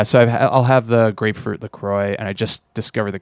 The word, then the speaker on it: LaCroix: LaCroix. – Is it LaCroix? – Yeah.